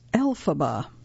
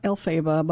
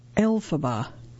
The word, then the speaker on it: Elphaba: It is actually pronounced Elphaba. Not Elphaba, but Elphaba.